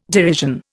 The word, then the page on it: derision: Indian